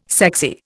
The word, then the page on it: sexy: Indian